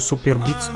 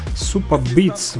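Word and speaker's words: Superbeets: А я же ему пишу, супер битс, чуть музыку что ли слушать, хорошие биты. А он смеется, говорит, нет, супа битс, в общем, э, пишется оно по-другому.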